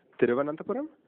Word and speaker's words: Thiruvananthapuram: It's Thiruvananthapuram.